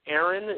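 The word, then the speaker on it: Arron: It's Aaron, and that's A-R-R-O-N, okay?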